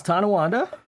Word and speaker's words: Tonawanda: It is Tonawanda.